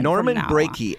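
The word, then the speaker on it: Norman Breakey: I believe it's Brakey. His first name is Norman, so maybe we'll just call him Norman. – Norman Brakey, as in heartbreaky.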